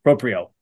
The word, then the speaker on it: Proprio: Proprio proprio.